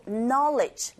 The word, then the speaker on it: knowledge: 끝발음에 강세 주지 마시고 knowledge, 널리지 아니에요. Knowledge.